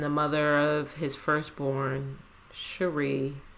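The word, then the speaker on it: Sheree: The next thing that I wanted to talk about was the photo of Will Smith and the mother of his child, Cherise. I think that's how you say her name. – I think it's just Cherie.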